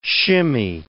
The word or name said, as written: shimmy